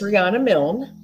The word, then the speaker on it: Riana Milne: It's Riana Milne.